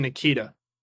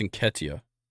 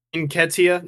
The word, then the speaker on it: Nketiah: I've always said Nikita. – I think it's Nketiah. – Nketiah? Yeah, okay, that makes more sense.